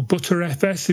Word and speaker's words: Btrfs: I have no experience with BTRFS or ButterFS, if you want to pronounce it that way.